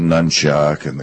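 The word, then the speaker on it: nunchaku: You know, during the feudal periods when samurai ruled in Japan, all of these Okinawan weapons that developed, like the nunchuck and the tanfa and some of the others, they were farm implements. The nunchuck, which is probably the most notorious, which I actually believe is pronounced nunchucka, that was a device that was used to separate rice the individual kernels of rice from the plant.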